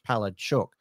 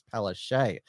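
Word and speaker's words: Palaszczuk: the nominations closed before Anastasia Palachuk, which is what we're told by... Polish Australians is the actual pronunciation of her name in Polish, Palachuk, but she's been telling us for years that it's Palache.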